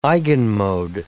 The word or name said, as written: eigenmode